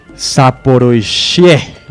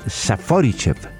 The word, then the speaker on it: Zaporizhzhia: que es Zaporizhzhia. Le pido ayuda al traductor oficial de los originales, Nicolás Amper. – Está pronunciándolo muy mal, es Zaporizhzhia.